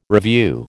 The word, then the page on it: revue: American English